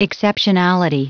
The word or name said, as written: exceptionality